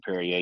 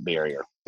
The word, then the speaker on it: Berrier: It sounds like Perrier the water with a b, and but except for you don't pronounce it Perrier, you pronounce it Barrier.